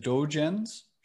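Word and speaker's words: Dōgen: Dogen's, uh, correct me if I'm pronouncing it wrongly, is it Dogen's Shobogenzo? – Dogen, yeah, Dogen. Dogen's Shobogenzo.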